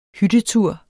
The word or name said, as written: hyttetur